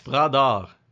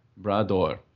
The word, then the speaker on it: Bras d'Or: It was called, it's French, so please excuse my pronunciation, but I believe it's called... Bras d'Or, yes. – Bras d'Or. – Brador.